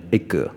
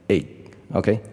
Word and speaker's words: egg: 啊、呃，之前我记得我举过一些例子，比如说鸡蛋，OK，E G G 啊，错误的人就是念 egg，对吧？但是应该是念 egg，OK，就是在喉咙结尾。